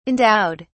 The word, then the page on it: endowed: British English